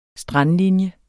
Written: strandlinje

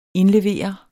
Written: indlevere

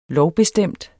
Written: lovbestemt

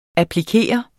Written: applikere

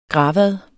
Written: gravad

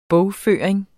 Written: bogføring

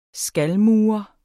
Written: skalmur

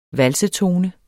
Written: valsetone

